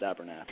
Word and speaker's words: Abernethy: So the story goes, so we're Abernethy, but it's actually pronounced Abernathy.